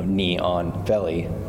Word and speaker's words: Neon Belly: so neon belly, even though it's spelled N-E-O-N.